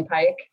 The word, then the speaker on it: Pyke: Emily Pike, P-Y-K-E.